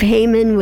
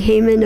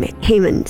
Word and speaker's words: Haman: But first of all, I would just like to own that I realized the pronunciation of Haman was actually Haman about three quarters of the way through the reading today. So Haman, who I introduced, then became Haman. Same person there, just for clarification.